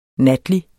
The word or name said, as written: NATLIGE